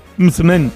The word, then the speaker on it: msemen: Una palabra complicada, decías M-S-E-M-E-N. Mzmen, mzmen, ¿vale? Y se pronuncia como lo ha hecho Hakima. Pero nunca, nunca se pronuncia como lo vamos a escuchar en boca de este TikToker, porque puede ocasionar confusiones.